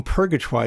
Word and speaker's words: Purgatoire: Because not only it's pronounced purgatoire, just so we know, you actually say the R at the end of it.